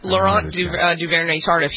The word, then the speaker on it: Laurent Duvernay-Tardif: What's the story of, is it Lawrence Duvernay-Tardif? Is that how you pronounce his name? – Mm-hmm. Yep, Laurent Duvernay-Tardif.